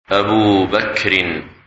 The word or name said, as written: Abu Bakr